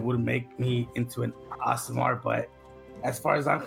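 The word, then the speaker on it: Aasimar: It's pronounced Asimar, but yes.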